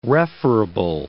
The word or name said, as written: referable